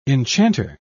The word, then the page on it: enchanter: British English